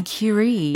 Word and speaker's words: Curie: Marie Curie. Marie Curie. – 어 발음이 퀴리 부인의 퀴리가 아니네요. – Mar- Marie Curie. – Oh, Curie.